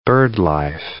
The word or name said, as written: birdlife